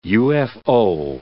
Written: UFO

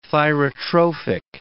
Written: thyrotrophic

